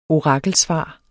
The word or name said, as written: orakelsvar